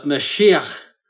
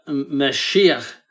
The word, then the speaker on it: Mashiach: took the uh, the guttural sound at the end of Hebrew words and made it an S because that guttural sound did not exist in Greek. So the original word is uh, Mashiach, Right.